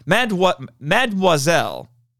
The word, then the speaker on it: Mademoiselle: And then, okay, this is a nitpick, but Kurt Russell Shaw, doesn't know how to pronounce Mademoiselle. Mademoiselle. He can't say it right. He keeps pronouncing it Mademoiselle. I mean, Kurt Russell, no, that's not how you say it.